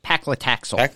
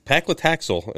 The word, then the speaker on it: paclitaxel: Paclitaxel. – Paclitaxel and two other taxanes, which are used in cancer medication.